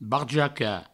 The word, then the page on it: barjacar: barjaca; Provençal